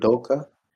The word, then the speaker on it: Udoka: But overall, I will say on the bright side, I really liked I can't say his name, but Ime Udoka, I think is his name. This the new Ime Celtic. Udoka. – Yeah, I believe that's how you say it. You sound like you said it better. That sounds right.